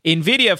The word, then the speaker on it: NVIDIA: Brent corrected me on how to pronounce this, NVIDIA, right? I was saying yes. NVIDIA. But like NVIDIA,